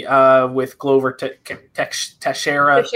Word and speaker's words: Teixeira: It's one of those names I can never Teixeira. – Teixeira, yeah.